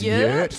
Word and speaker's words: yurt: I live in an ERT. It's like a type form of teepee. – Isn't it pronounced yurt? – Yurt, yes. Yeah, sorry. I like to say ERT.